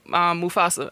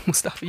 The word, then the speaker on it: Mufasa: I wanted to be me, Smiley, and Mufasa. I almost called him Mustafa. You about to have me saying it wrong.